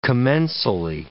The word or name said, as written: commensally